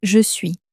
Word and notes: je suis: french

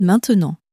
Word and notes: maintenant: french

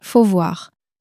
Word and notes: faut voir: French

